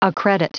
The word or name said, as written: accredit